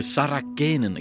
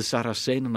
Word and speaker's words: Saracenen: dat ik toen verkeerd uitsprak: de Sarakenen. Ik dacht dat het zo moest uitgesproken worden. De Saracenen natuurlijk.